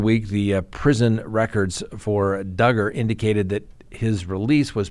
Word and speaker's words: Duggar: Josh Duggar rhymes with hugger.